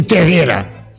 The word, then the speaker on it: Tejeira: Escreve Tejeira, mas pronuncia-se Terreira.